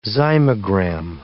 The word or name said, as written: zymogram